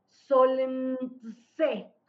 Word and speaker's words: Solntse: Solentse, ah, ¿verdad? Así se escribe S-O-L-N-T-S-E. Solentse, esa, ¿no?